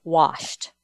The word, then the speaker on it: washed: I hope my youngest son-in-law is listening, because I said it right. I didn't say washed. I said washed. No R.